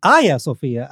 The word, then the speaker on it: Hagia Sophia: The Hagia Sophia, as the Turks would pronounce it.